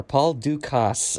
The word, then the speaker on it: Dukas: So many people think that the French pronounce the name without the S. Dukas, I've heard that many times. But he, the composer, explicitly told everyone to pronounce the S.